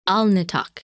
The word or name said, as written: Alnitak